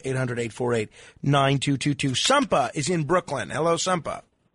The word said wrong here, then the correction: Sante: Nah, it's Santa. Santa from Brooklyn. – It's what from Brooklyn? – Santa. Santa. – Spell it for me. – S-A-N-T-E. – S-A-N-T-E. – T-A-T-A. – Santa, like Santa Claus? – Yes, sir.